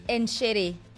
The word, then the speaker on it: Enschede: What is it? – Enschede. – So if my producer says it's Enschede, then it's Enschede.